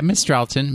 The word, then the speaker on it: Mistralton: Mistralton? I don't know how to say these. – Yeah, Mistralton. Mistralton City.